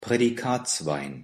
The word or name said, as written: Prädikatswein